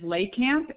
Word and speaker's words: Lakamp: My last name is Lakamp. It's L-A-K. A-M as in Mary, P, first name Heather. – L-A-K-A-M-P? – Correct.